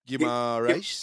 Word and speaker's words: Guimarães: Guimarães. – Guimarães.